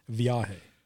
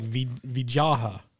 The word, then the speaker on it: Viaje: Viaje. Yeah, you went like Vijaha.